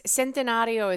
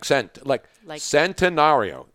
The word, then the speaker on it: Centenario: It's Centenario is C-E-N. – That's what I said, like Centenario. It's spelled, you know. – Like century.